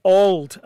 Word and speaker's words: Auld: And it's Auld, as in A-U-L-D, not Auld Lang Syne.